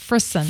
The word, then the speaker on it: frisson: F-R-I-S-S-O-N. – Oh. – Frisson.